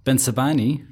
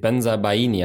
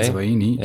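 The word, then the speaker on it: Bensebaini: Ben Sabani, have I pronounced that right? – I don't know. I'm on parental leave. Ben Sabaini, I would say. – Ben Sabaini. – Yep.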